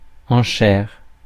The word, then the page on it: enchère: France; /ɑ̃.ʃɛʁ/